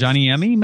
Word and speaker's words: Rajaniemi: Then on the 10th, The Quantum Thief by Hanu Rajimeni. I hope I'm pronouncing that close. Rajaniemi, maybe? – Rajaniemi.